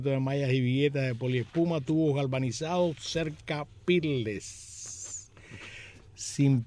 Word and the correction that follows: Peerless: Ah, porque puso perle. Y el productor pone peerless, como se escribe, peerless, sin peladura.